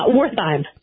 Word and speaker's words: Wertheim: Wertheim. – Wertheim? – Yes. – Okay. Like Werther's.